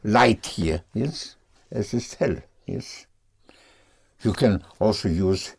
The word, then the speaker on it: hell: But pronounced hell, not hell. yes, hell.